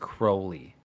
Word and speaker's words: Crowley: He rhymed his own name with Holy, which implies that his last name is pronounced Crowley.